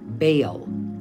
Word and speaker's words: Baal: you pronounce it Baal.